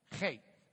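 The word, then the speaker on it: Chet (חית): So I just, it's kind of like a CH in the sense of Bach, not in the sense of Charlie. Okay, so it's the CH like in Bach or something. Chet.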